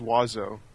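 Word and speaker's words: oiseau: Isn't it Avion? – It's just Wazo.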